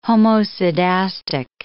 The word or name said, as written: homoscedastic